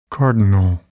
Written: cardinal

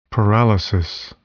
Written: paralysis

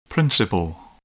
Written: principal

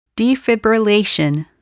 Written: defibrillation

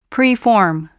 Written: preform

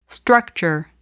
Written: structure

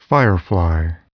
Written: firefly

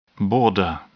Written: border

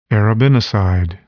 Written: arabinoside

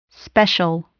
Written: special

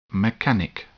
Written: mechanic

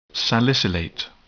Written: salicylate

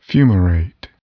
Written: fumarate

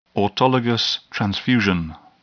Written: autologous transfusion

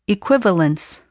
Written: equivalence

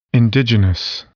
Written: indigenous

